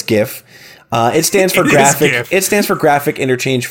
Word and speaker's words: GIF: It's GIF. Okay. So graphic exchange interform, it's a hard G.